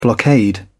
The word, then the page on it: blockade: British English